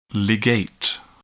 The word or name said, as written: ligate